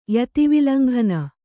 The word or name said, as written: ಯತಿವಿಲಂಘನ